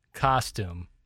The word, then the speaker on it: costume: I think if a ka, that's got the emphasis at the first syllable. – Yeah. – Stum. – Costume.